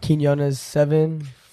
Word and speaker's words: Quinones: Not Quiznos, Quinones.